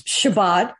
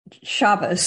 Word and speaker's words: Shabbat: Well, the course Shabbat is the Hebrew form of the word Sabbath. – Yeah. – And in Yiddish, it's Shabbos. So yes. – It's just the pronunciation here, but we are talking about the Sabbath.